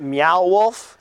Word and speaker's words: Meow Wolf: So we can concentrate on an awesome tournament, the Galaga World Championship at Meow Wolf. Am I saying it right, Adam? – You still are? – Yes!